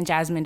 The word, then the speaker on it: Jazmyn: That's Comedian Jazmine W on YouTube. And my first name is spelled J-A-Z-M-Y-N, thanks to my dad.